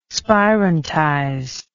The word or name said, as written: spirantize